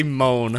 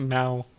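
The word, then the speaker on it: moue: I always say moan. – I always moan when I have to cut the lawn too. – I don't know. I thought we were just making random noises. – Pronounce it mow.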